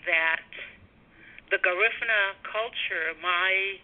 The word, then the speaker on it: Garifuna: And for people who may not be familiar with the location of where, am I pronouncing it? Is it Garifuna people? That's the right pronunciation? – You can say Garifuna, and I say Garifuna.